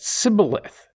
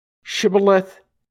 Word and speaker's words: Shibboleth: When he said no, they said to him, "Then say Shibboleth," and he said Sibboleth, for he could not pronounce it right. Shibboleth, Sibboleth.